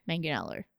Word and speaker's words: Manganiello: Yeah, there is an I, but you don't really pronounce it. You just say, okay. Like, Manganiello.